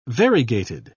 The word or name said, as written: variegated